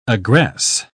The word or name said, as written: aggress